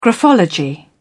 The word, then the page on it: graphology: British English